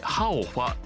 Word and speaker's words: HAOFA: HAOFA というアルファベット 5 文字でハオファと読むみたい